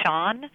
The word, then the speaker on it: Chaon: It's a quote from an interview that I read with the novelist and short story writer Dan Shaun. His last name is spelled Chaon,